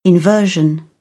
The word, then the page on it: inversion: British English